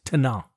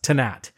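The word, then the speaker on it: Tannat: Now, on a side note, in France, the wine is pronounced without the T at the end, so it's Tanat. – Of course. – But in Uruguay, it's pronounced Tanat. And when you're looking for the French version,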